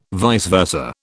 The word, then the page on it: vice versa: British English